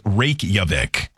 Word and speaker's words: Reykjavik: I called it Reykjavik, but... – Yeah, either way. – They say, and on this pronunciation on her sheet, it says Reykjavik.